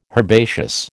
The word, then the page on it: herbaceous: American English